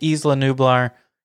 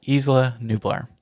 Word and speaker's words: Isla Nublar: So we went with Isla Nublar. You may have heard a different pronunciation, but in Jurassic World, whenever Claire's nephews are sailing in to Jurassic World, there is a recording that says Isla Nublar. So that's what we're going with.